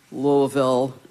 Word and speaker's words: Louisville: And I started coming to Nashville in the 70s and heard Louisville the first time, where you kind of bury the IE. – Louisville, Louisville, Louisville.